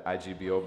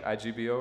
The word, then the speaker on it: Igbo: Am I saying that right? IGBO or is it Igbo? – It's both. – All right, Igbo. I wasn't sure. I was like... – You could say it both.